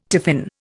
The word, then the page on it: tiffin: Indian